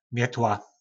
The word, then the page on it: metla: po nasimu